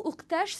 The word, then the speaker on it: ouqtach: Donc, on dit soit oktash, soit imta. – Imta ou oktash. – Oktash. Voilà. Donc, pour oktash, ça s'écrit de la manière suivante. O-U-Q-T-A-C-H. – O-U-Q-T-A-C-H.